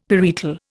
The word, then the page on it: parietal: Indian